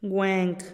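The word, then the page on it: guenk: Teotitlán del Valle Zapotec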